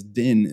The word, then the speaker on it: DIN: Well, you guys are already saving like time on your lives, because DIN is just one syllable and NDC is three syllables.